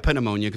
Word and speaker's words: pneumonia: By the way, I say pneumonia because I think that P has rights.